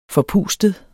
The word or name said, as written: forpustet